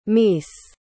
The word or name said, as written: meese